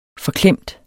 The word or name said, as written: FORKLEMT